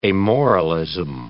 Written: amoralism

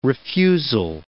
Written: refusal

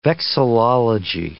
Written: vexillology

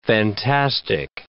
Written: fantastic

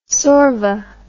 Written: sorva

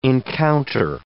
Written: encounter

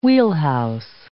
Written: wheelhouse

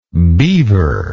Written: beaverish

